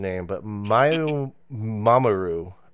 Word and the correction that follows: Mamoru Miyano: Mamoru Miyano? – Yes.